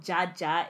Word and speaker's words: Djadja: Yeah, there's this song called Jaja. It's spelled DJADJA by some babe called Aya Nakamura.